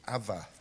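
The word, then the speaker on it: Abba (אבא): In Hebrew they would pronounce it Abba.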